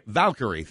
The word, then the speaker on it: Valkyrie: Valkyrie, thank you.